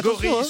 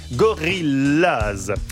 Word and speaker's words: Gorillaz: Gorillaz se prononce comme en anglais, Gorillaz.